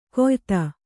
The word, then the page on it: ಕೊಯ್ತ: koyta